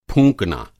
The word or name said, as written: फूँकना